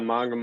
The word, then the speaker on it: Monga: Yeah, Monga.